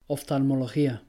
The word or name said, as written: oftalmología